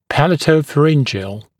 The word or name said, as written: palatopharyngeal